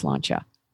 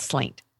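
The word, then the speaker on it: sláinte: And I went up to like Pete and I was like, it's pronounced slancha. It's not slaint.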